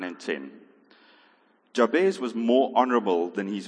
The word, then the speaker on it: Jabez: So Jabez was, and I pronounce it Jabez. You can pronounce it whichever way you like. We had a debate at Life Group how you pronounce it, but I'm standing in the front, so I say Jabez.